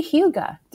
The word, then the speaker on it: hygge: hygge. – Do you mean eat a little more Danish? Because I would like to do that. – That would be very hygge.